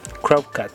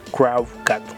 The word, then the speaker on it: Crowcat: A ver se llama Crow, no sé cómo se pronuncia, se llama cat Supongo que se pronuncia así, cat Pero se escribe, se escribe C R O W B larga C A T. Crowcat, Crowcat.